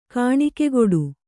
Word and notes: ಕಾಣಿಕೆಗೊಡು: Kannada

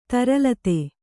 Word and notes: ತರಲಿತ: taralita

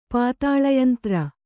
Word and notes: ಪಾತಾಳಯಂತ್ರ: pātāḷa yantra

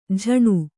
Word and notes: ಜಣ: jaṇa

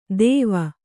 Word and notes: ತೇವ: Kannada

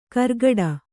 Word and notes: ಕರ್ಗಡ: kargaḍa

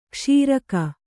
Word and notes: ಕ್ಷೀರಕ: kṣīraka

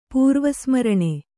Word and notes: ಪೂರ್ವಸ್ಮರಣೆ: pūrva smaraṇe

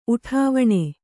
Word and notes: ಉಡಿವಣಿ: uḍivaṇi

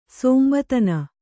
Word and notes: ಸಂಬೋಧನೆ: sambōdhane

